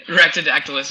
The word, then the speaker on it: Rhacs: And when we say Reed's Rhacs, it's R-H-A-C. – Yeah, it's not. Yeah, it's Rhacs, right? Is that how you say it? – Yeah. Rhacs. Yeah.